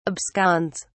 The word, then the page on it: absconds: British English